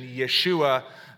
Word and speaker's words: Yeshua: And he was converted during the time of the 60s to what the Jewish believers call Yeshua, Jesus Christ, the Hebrew pronunciation Yeshua, the long-awaited Messiah of Israel.